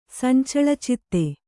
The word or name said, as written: ಸಂಚಳಚಿತ್ತೆ